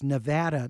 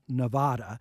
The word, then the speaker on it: Nevada: I think residents pronounce it different from those who speak Spanish. In fact, the Nevada Visitors Bureau actually runs with some of their ads a little pronouncer for it, that it's Nevada, not Nevada.